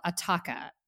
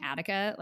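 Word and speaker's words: Attacca: The album is called Ataka. And I'm making sure I pronounce that right because in my brain, I just kept saying Attica, like the prison.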